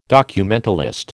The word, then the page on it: documentalist: American English